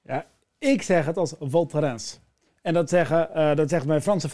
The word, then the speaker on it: Val Thorens: Ik maakte die fout vroeger ook, maar het is niet de Ans. Dat is het. – Val Thorens. – Val Thorens, ja. Val Thorens. – Dus wel met die uitgesproken S erachter.